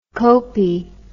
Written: kopi

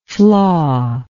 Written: flawy

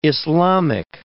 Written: Islamic

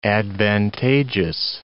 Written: advantageous

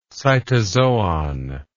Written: cytozoon

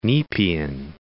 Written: Nepean

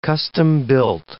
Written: custom-built

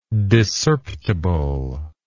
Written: discerptibleness

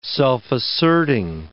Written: self-asserting

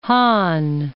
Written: Hahn

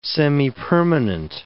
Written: semipermanent

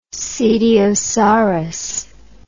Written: cetiosaurus

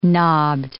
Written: knobbed

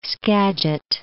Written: Skagit

